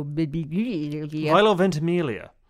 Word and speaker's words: Milo Ventimiglia: Milo Ventimiglia.